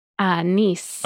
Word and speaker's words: Nice: So the city in the south of France, how do you say that? – Nice.